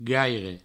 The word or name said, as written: gaire